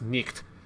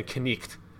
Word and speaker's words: night: So one example is like the word night, N-I-G-H-T. That G-H-T sound that we now no longer pronounce in any meaningful way back then would have been pronounced nicht, or night would have been knicht. So you would have originally pronounced the K and had this like sound for the G-H-T.